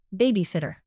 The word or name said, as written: babysitter